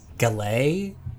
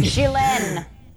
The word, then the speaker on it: galette: Galane? I don't know how to pronounce this thing. – Gilen. It's pronounced Gilen.